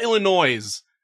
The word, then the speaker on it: Illinois: Do you say, I call it Illinois.